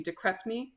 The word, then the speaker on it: De Crespigny: The one in the center there, Mrs. De Crespigny, or I think in the British pronunciation it would be De Crepney, is how it would be said,